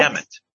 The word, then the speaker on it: Demet: Am I saying it right? – Yes, that's it. – Demet.